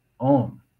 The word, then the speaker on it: Om: And so Om is pronounced A-U-M, Om.